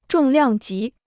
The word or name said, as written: 重量级